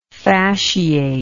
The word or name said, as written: fasciately